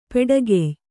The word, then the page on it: ಪಾಡುಗಾಯಿ: pāḍugāyi